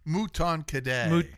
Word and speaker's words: Mouton Cadet: Mouton Cadet. – Mouton Cadet, excuse me. – Yes, okay. Mouton Cadet.